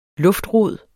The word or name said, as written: luftrod